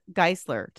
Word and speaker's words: Geissler: Olga Geissler.